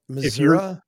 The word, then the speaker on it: Missouri: Missouri? – If you are not in Kansas City or St. Louis, it's pronounced Missouri.